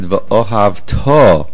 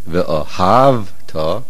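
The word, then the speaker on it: V'ohavto (וְאָהַבְתָּ): You must pronounce it V'ohavto, putting the stress on the ta ending, because then it means you shall love Hashem. However, if you put the stress on the hey and you said ve'ohav ta, the meaning is a past and you loved. The vav at the beginning is a vav hamahapeches, and therefore the stress must be at the end.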